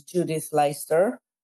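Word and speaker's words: Leyster: The first one that we were looking for is the artist, I'm going to mangle her name, but it's known in the West at least as Judith Leister. Is there a better Dutch pronunciation of that? – Well, that's very accurate. Yes, absolutely.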